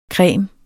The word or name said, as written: cremes